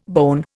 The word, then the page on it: bone: Indian